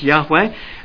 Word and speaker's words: Yahweh: It's four words in the Hebrew, which is the original language it was written in, YHWH, pronounced Yahweh, and basically it's God's name.